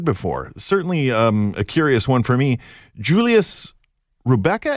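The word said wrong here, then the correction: Reubke: It's Reibke.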